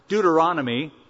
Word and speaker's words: Deuteronomy: Now, Deuteronomy is a hard word. It's five syllables, but it's an easy meaning.